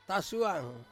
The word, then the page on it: suang: Ansus